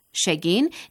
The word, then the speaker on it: Shegin: Shegin is the local pronunciation of Shogun, meaning omen.